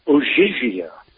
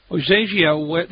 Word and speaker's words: Ogygia: Well, in our next piece, we're going to be talking about overcoming a woman, and that is Odysseus on, and is it pronounced Ogygia? – I believe the Greek is Ogygia. – Ogygia, this is where Calypso kept Odysseus for 7 years and wouldn't let him go.